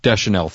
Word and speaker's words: Deschanel: A thoroughly enjoyable, fun film, lighthearted, called 500 Days of Summer, which stars, and I love her, Zoe, I'm going to butcher her name, Zoe. – Zoe Deschanel. – Deschanel, thank you.